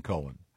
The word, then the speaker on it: Cohen: Leonard Cohen. – This gets confusing because... – No, not when it's spelled C-O-H-E-N. – It's definitely Cohen.